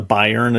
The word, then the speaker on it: Bayern: Is it okay if I call it The Bayern Agenda? – Yeah, I've been told that that is the correct way to say it in German, and you know why I say that?